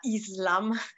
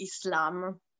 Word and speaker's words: Islam: Cominciamo, ecco, proprio dalla prima parola, che è Islam, una parola, ecco, che a volte in italiano viene pronunciata Islam, ma è che in realtà in arabo, ecco, si pronuncia così, Islam.